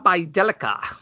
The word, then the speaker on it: Bjelica: How do you say his name? Bajelica? Oh. Bajelica. – Bajelica. – Bajelica. – This is fun. This is better than the NFL. – Yeah, Nemanja Bjelica. – Bjelica, right.